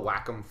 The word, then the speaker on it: Wacom: W-A-C-O-M. – Oh, Wacom. Wacom. – Yeah. – Yeah. – Well, that's how they pronounce it. The company pronounces it Wacom, so...